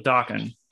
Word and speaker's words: Dokken: Rockin' rhymes with Dokken.